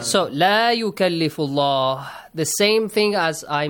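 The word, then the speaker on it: La yukallifullah (لا يكلف الله): Do not, be careful, don't pronounce it la yukallifullah. Yep. La yukallifullah, because the word la is kind of confusing for some of the students. If you say la yukallifullah, it means for sure Allah what? Tasks any soul beyond the capacity. This is wrong, yes? This is not what the ayah says.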